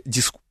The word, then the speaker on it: дискурс: Окей, окей, но зато я могу показать тебе высокое э, знание дискурса. Почему дискурса? Дискурса же. – Там, по-моему, вариативное ударение.